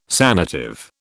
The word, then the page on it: sanative: British English